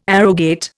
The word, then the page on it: arrogate: Indian